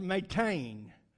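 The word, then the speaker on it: metain (μάτην): That word in the original in the Greek is pronounced metain.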